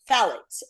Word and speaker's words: phthalates: Phthalates. Steve said the word is phthalates. Thank you. The T is silent, he says.